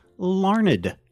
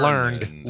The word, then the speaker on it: Larned: It is pronounced Larned, Kansas. – It is Larned. Okay, so not learned. Larned.